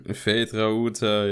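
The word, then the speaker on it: Feyd-Rautha: Aber ich fand, jetzt muss ich den Namen richtig aussprechen, Fate Rauter Harkonnen. – Fate Rauter, ja. – Ja, genau. Den fand ich irgendwie ganz cool.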